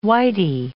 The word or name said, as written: whity